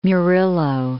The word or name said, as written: Murillo